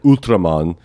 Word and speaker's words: Ultraman: The other clip I want to play though is that, so then there was this popular Japanese show for kids called Ultraman, which is kind of pronounced like Ultraman, which you might want to know for the clip.